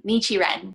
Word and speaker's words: Nichiren: Nichiren.